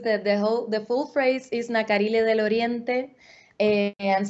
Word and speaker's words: Nacarile: And forgive me if I say it wrong, Nacarile, is that how you say it? Or say the name for us and tell us more about it. – Yeah, Nacarile, you said it perfectly.